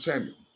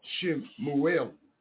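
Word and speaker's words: Shmuel: A lot of you call it Samuel. That is not the correct pronunciation. That is the English-Greek pronunciation. The correct Hebrew pronunciation, Shemuel.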